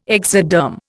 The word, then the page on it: exoderm: Indian